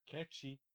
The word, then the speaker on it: Nkechi: How do you say it? In Ketchi or Nkechi? – Ketchi. Uh, Ketchi.